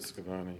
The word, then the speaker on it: Schiavone: David Scavone. – How do you spell that? – S-C-H-I-A-V-O-N-E.